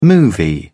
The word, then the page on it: movie: British English